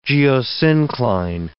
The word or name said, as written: geosyncline